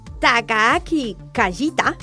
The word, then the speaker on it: Takaaki Kajita: el japonés Takaaki Kajita.